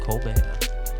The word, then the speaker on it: Colbert: Shout out Stephen Colbert. How do you say his last name? – Stephen Colbert.